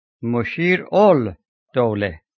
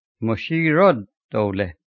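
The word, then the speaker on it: Mushir-ud-Dawla: In Arabic, it is written Mushir-ul-Dawla, but it is pronounced Mushirud-Dawla.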